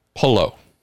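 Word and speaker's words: Palou: So Alex from Spain, Spanish, his mother tongue, from the country where this mother tongue sprang forth, Polo. That's how he pronounces his name,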